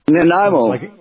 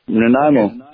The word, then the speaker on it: Nanaimo: We got a call from, I don't know if I can pronounce this right, Nanaimo, British Columbia? – Nanaimo. – Nanaimo. – Nanaimo, yeah.